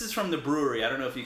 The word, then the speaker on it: Bruery: Well, it's spelled B-R-U-E-R-Y, brewery. So yeah, it's a little bit hipster.